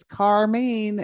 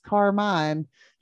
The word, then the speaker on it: Carmine: And she came on and she said, nope, it's Carmine. And everyone says Carmine. So for all you 1A schools out there, if you run up against Round Top Carmine, remember, it doesn't sound like it's spelled.